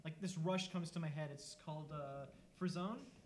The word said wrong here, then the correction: frisson: It's just you get this sort of tingling sensation in your brain, and it just I believe it's pronounced frisson.